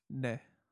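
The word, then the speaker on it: Ne (네): Ne. – Ne? – Or yeah. – What the fuck? – Either one is good. – Yeah. Ne is very...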